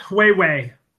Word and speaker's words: Huawei: Hawaii? – It's Huawei. – Huawei? – Yeah, I think so. – New Huawei.